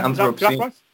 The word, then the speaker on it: Anthrobscene: is it Anthrobscene? – Yeah, Anthrobscene.